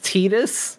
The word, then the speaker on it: Tidus: I believe it's pronounced Tidus, but I would rather say Tidus if you're going to ask me.